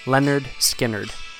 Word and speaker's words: Lynyrd Skynyrd: Tuesday's Gone, Lynyrd Skynyrd, pronounced Leonard Skinnerd, 1973.